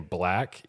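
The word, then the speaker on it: 6LACK: But I think one of the albums recently that I really played a lot was, I think you pronounce his name Black, even though it's spelled mm-hmm. with a six on the front.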